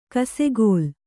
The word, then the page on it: ಕಿಸುಗಲ್ಲು: kisugallu